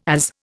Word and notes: AS: Indian

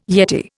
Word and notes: yeti: Indian

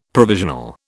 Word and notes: provisional: British English

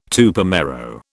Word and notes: Tupamaro: British English

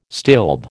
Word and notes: stilb: American English

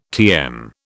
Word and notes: TM: British English

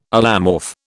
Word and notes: allomorph: British English

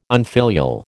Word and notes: unfilial: American English

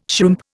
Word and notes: shrimp: Indian

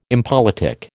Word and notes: impolitic: American English

